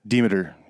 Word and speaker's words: Demeter: Yeah, Demeter. – Demeter. – Yeah. – I didn't pronounce it right. – Yeah, no, long E.